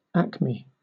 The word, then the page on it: acme: British English